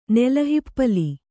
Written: ನೆಲಹಿಪ್ಪಲಿ

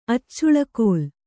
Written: ಅಚ್ಚುಳಕೋಲ್

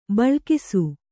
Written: ಭಾಳಾಕ್ಷ

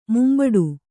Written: ಮುಂಬಡು

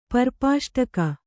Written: ಪರ್ಪಾಷ್ಟಕ

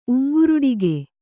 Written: ಉಂಗ್ರುಡಿಕೆ

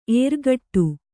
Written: ಎರಗಾಡು

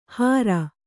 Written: ಹಾರ

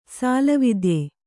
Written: ಸಾಲವಿದ್ಯೆ

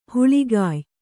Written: ಹಾಳ್ಗೆಯ್

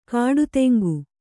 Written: ಗಿಡುತಿಂಗಿ

